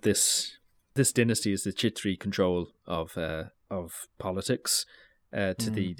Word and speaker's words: Chhetri: And most of the rulers, I think the Shah family and all of the military leaders within their kingdom would have been Chhetri, is that how we said we pronounce the word? – Chhetri. – Yeah.